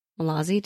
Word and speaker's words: Mallozzi: Yeah, but I did read uh Mallozzi's Mallozzi. Did I say that right? – Yes, he did.